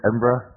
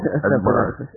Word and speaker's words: Edinburgh: Edinburgh. – Edinburgh. – Edinburgh, Scotland, yeah. Just say Edin and then cough.